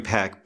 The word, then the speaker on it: Peck: Peck, It's Gregory Peck and Lauren Bacall. – The ER is silent. Babes. – Bubs. – Yeah. What? The ER is silent. – No. – In a lot of cases, but I pronounce it because I'm an American. – No, his last name is Peck.